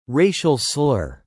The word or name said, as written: Racial slur